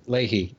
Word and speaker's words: Leahy: Leahy or Lehi? I can never remember how to pronounce that. – Leahy.